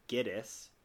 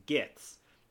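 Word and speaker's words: Gittes: which is Giddis, and the villain mispronounces his surname as Gitz, making it sound even more Ashkenazi.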